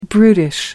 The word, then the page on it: brutish: American English